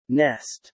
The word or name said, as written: nest